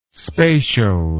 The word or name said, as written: spacial